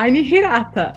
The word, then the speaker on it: Hirata: Que é arroba Hirata.